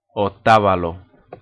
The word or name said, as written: Otavalo